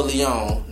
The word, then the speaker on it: Leon: A-O-H. – Okay. – P-H-A-R-A. – A-O-H. Spell Leon for the people. – L-E-O-N. Leon. I do got to spell that because everybody be getting my name messed up. It's Pharaoh Leon, not Pharaoh Leon.